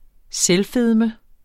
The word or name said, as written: selvfedme